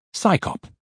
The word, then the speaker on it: CSICOP: Its acronym, CSICOP, is pronounced PSYCOP, as if it's an organization of scientists performing a police function.